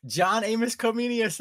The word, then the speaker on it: John Amos Comenius: John Amos Comenius.